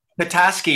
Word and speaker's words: Petoskey: And you guys, you grew up together in, is it Petoskey, Michigan? Is that how you say it? – Petoskey, yeah. P-E-T-O-S-K-E-Y.